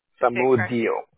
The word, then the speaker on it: Samudio: So for your first name, is it J-O-S-U-E? – Yeah, that's it. – Last name, I have Samundo, S-A-M-U, I said it wrong, S-A-M-U-D-I-O, Samundo. – Samudio. – Mudio. Did I forgive me? – Yeah, Samudio. No, you're quite right.